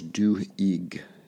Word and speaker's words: Duhigg: The other book is called The Power of Habit, which is, I think, by Charles Duhigg is how you pronounce his name.